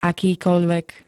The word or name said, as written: akýkoľvek